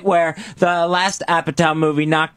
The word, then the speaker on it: Apatow: Also, on demand, This Is 40, the new movie from, I believe you pronounce this, Judd Apatow.